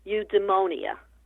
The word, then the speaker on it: eudaimonia: So again, this is a Greek term, and I'm trying to say it correctly, eudaimonia.